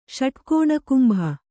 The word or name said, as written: ಷಟ್ಕೋಣಕುಂಭ